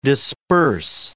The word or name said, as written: disperse